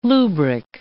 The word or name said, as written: lubric